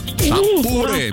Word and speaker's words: шампуры: Итак, запомните, Олечка, и вы особенно, что везде, как бы вы там ни переворачивали, ударение во всех формах существительного падает на букву «у». Шампуры, металлические шампуры, жарить на шампуре, есть с шампура, ну и так далее.